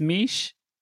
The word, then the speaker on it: Miche: My name is Miche, like Quiche, in case you're wondering how to pronounce that.